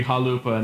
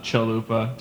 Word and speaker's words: Chalupa: Yeah, it's just like the proper way to pronounce my name, where it's supposed to be Chalupa and not Chalupa.